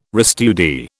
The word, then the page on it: restudy: British English